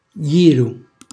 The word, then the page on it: gghjru: Calabrese